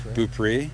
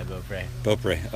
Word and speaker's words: Beaupré: It's like Beaupré. – Beaupré? Yeah, Beaupré. Okay.